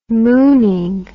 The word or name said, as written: mooning